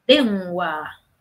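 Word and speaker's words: Denwa (電話): Denwa.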